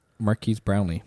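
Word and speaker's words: Marques Brownlee: Marquise Brownlee.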